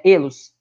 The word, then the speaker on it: elo: elos, né? e pronuncia assim mesmo, elo, com a tônica aqui no, na, primeira, na primeira sílaba, elo, tá?